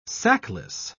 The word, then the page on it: sackless: British English